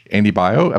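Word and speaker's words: Baio: So, but then it turns out there's Andy Bio. I believe I'm saying his name correctly. – Yes.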